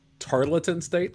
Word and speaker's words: Tarleton: It's Tarleton State. – Tarleton State? That's correct, right? – Yeah.